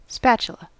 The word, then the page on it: spatula: American English